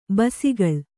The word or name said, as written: ಬೆಸಕೋಲು